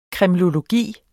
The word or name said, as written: kremlologi